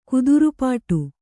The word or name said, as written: ಕಾತರಪಡು